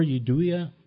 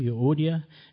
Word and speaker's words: Euodia: I implore Euodia, and I mispronounced that name big time, Euodia, and I implore Syntyche to be of the same mind in the Lord.